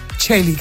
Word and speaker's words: Coeli: A la radio del Obispado, la radio Regina Celi, que se escribe Regina Coeli, que Celi en latín es Coeli.